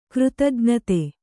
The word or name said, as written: ಕೃತಜ್ಞತೆ